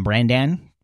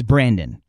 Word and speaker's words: Brandan: Uh, whenever I see his name, I want to call him Brandan because it's it's spelled B R A N D A N, but it's Brandon not Brandon, Brandon.